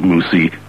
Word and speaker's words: Moussy: Spelled M-O-U-S-S-Y, but pronounced so as to rhyme with Debussy.